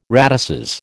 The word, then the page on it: radices: American English